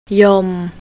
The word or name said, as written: yom